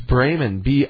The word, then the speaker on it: Bremen: How do you pronounce Bremen, the city in Germany? Is that Bremen? – It's Bremen. Bremen. Bremen. – Bremen. – Bremen. – That's pronounced correctly.